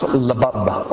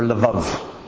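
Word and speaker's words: labab (לבב): And it's pronounced labab or lavav.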